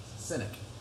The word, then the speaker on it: Sinek: Uh, I think it was Simon Sin, Sindak. Sindak. How do you pronounce his name, Ben? Sindak. Is that right? Sindak. Sinek he wrote a book called Starting with the Why, and I think we should always ask the why question of everything we're doing.